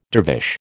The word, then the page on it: dervish: American English